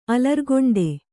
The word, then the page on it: ಅಲರ್ಗೊಂಡೆ: Kannada